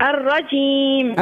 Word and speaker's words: الرجيم: أعوذ بالله من الشيطان الرجيم. – لا مش الرجيم. شو الرجيم؟ الرجيم بين الجيم. – أعوذ بالله من الشيطان الرجيم. – علاش اشبيه الجيم تنطق فيها هكاك ترى كيفاش تعاود الرجيم.